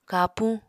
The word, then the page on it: kapuñ: Apatani